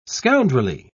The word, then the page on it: scoundrelly: British English